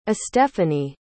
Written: estephanie

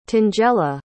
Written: tangela

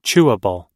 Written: chewable

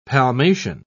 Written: palmation

